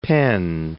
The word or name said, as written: Penn